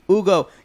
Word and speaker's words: Hugo: Don't pronounce the H. – Just Hugo.